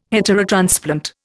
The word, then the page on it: heterotransplant: Indian